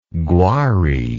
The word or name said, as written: gwari